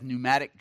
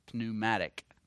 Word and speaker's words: pneumatic: Pneumatology. Silent P. Danny knows that because he's a construction man. He has pneumatic drills. P-N-E-U-M-A. Pneumatic. Pneumatic. P is silent.